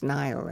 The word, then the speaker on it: Niall: Oh, our son is called Neil, and it's spelled N-I-A-L-L, which is pronounced Nile over here, so he calls it Nile now because he got tired of telling, telling people.